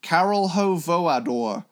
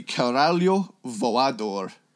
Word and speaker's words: Caralho Voador: And I actually know how to say this properly, but I'm going to say my original dumb 14-year-old pronunciation of it. Caralho Voador. It's actually Caralho Voador. – Oh. – If I am correct.